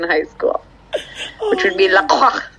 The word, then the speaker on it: LaCroix: Marcia just paused her story and drank some LaCroix. LaCroix, sorry. Bad pronunciation there. – Well, who was it who explained it? Oh, on their website, it says, it's pronounced LaCroix, like, enjoy. Rhymes with enjoy.